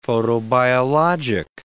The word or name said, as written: photobiologic